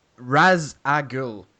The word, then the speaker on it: Ra's al Ghul: Ra's al Ghul, okay, is how I say it. Many people say Ra's al Ghul.